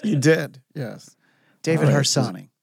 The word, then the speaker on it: Harsanyi: How do you say this guy's name? – It's David Harsanyi.